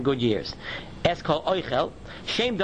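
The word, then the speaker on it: oichel (אוכל): That's the difference between oichel, which is a noun, where the accent is on the aleph and there is a segoil under the chaf, oichel, that means food. But when the accent is on the end of the word, and there is a tsere under the chaf, oichel, then it is a verb and it means to eat.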